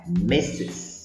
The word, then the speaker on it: Mrs: Aqui a pronúncia muda bastante da forma escrita e muitas vezes vejo os alunos pronunciarem Miss como misters, como se fosse um plural de masculino. E isso é muito errado, hein, galera? Presta bastante atenção para não cometer esse erro, tá? A pronúncia correta é Mrs. Vamos para um exemplo?